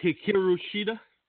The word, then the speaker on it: Hikaru Shida: Hikaru Shida. – Yes, you said that right. – All right. Hikaru Shida defeated Nyla Rose.